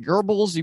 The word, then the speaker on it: Goebbels: Is it like Goebbels? You pronounce the R? – Yeah. Oh, there's not an R in it, but that's how you pronounce it.